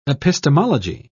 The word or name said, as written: epistemology